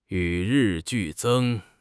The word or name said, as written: 与日俱增